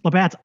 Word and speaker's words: Labatt: Do you pronounce it Labatt? We just call it Labatt.